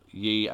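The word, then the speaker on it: Yi: These accusations have come from Charlene Yee. I think that's how you say her name. Charlene Yee, as in Y-I.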